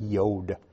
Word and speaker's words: yod: It's pronounced like a Y, that yod.